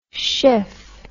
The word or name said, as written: Schiff